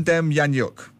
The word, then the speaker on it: Demjanjuk: Now, we get to the other guy, John Demjanjuk. Did I say it right? – Yeah, perfect. – Demjanjuk.